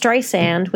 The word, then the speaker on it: Streisand: I really like her quote about it where she said, "It's Streisand with a soft S like sand on the beach.